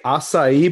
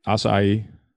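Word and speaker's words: açaí: Do you say acai berry or acai berry? – Acai. – Thank you. – It's acai. It's definitely acai.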